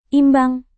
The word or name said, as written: imbang